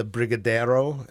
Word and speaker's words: brigadeiro: brigadeiro.